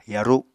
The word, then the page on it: yaru: apatani